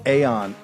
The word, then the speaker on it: Aeon: Joe Allen, Dark Aeon. And I'm going to talk to you about how I came now to the conclusion Aeon's the appropriate way to pronounce it, no matter what Cambridge says.